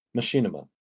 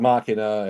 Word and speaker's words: Machinima: Machinima. Machinima. Mm. That is Machinima. Yep. My God, the machine. – Mm-hmm. Um, not Machina, as in Deus Ex Machina, but but, but Machinima because it's being made in a machine.